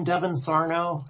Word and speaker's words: Sarno: Yeah, so just my name, Devin Sarno.